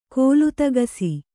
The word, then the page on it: ಕಾಲ್ತೆಗಿಸು: Kannada